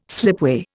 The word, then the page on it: slipway: Indian